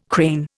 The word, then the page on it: crane: Indian